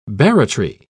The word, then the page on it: barratry: British English